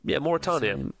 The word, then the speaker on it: Mauritania: And finally, Mawia from Mauritania. Is it pronounced Mauritania? Mauritania. Currently living in Malaysia. – Mauritania, yeah.